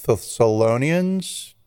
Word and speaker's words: Thessalonians: Thessalonians, did I pronounce that close? – Mm-hmm.